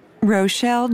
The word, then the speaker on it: Rochelle: D-I-E-T-E-R-Q-U-I-N-N. – 3. – First and last name, please. – Rochelle Johns. – How do you spell that? – R-O-C-H-E-L-L.